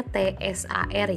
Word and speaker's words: Tsar: tulisnya T S A R ya, tapi dibacanya Tsar Nicholas yang kedua.